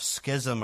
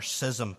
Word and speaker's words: schism: Secondly, I want us to consider here a definition of schism or schism. People pronounce it in different ways.